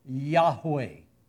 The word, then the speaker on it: Yahweh: Well, we know basically from the research of scholars and from tradition and from some things in the scripture that this is actually pronounced Yahweh.